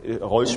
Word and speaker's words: Rawls: Rawls spricht Rolls spricht sich aber,